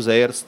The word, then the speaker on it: Zeyer: Und das galt zu Sayers Zeit. Ich sage Sayer, man könnte aber auch Zayer sagen, man weiß nicht genau, wie er sich selbst ausgesprochen hat. Äh, heute wird er in Tschechien als Sayer ausgesprochen.